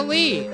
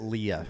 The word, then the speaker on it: Lea: How to spell my last name is L-E-A. So everyone pronounces it Leah.